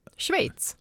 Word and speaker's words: Schweiz: Det ena är, hur uttalar man det där landet? – Schweiz. – Ja, exakt.